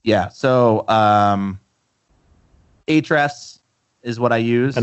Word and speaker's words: Ahrefs: But from the company, it's Ahrefs is the is how they say it. The A is silent.